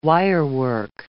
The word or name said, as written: wirework